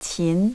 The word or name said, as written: qin